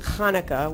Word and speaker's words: Hanukkah: The miracle of Hanukkah, and it is pronounced Hanukkah, which is why it's so hard to spell, because, you know, it's either going to be Chanukah with a C-H or just Hanukkah with an H.